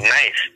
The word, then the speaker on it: knife: the K is silent. How you spell knife? He said, I'm in school, spell knife, N-I-F-E. He said, no, it got a K in it. He said, but you said knife. K would be cut knife. But they put the silent K.